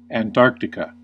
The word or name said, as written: Antarctica